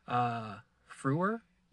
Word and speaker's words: Frewer: Just like, what was that guy's, the actor's name was Matt, I think I'm saying this right, Frewer? – Yeah. – F-R-E-W-E-R?